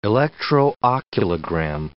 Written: electrooculogram

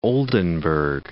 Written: Oldenburg

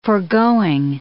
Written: forgoing

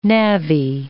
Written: navvy

